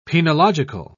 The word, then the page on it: penological: British English